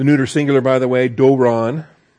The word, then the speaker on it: doron: Doron. D-O-W-O-N. D-O-R-O-N. The long O is the omega, the short O is the omicron. You have doron.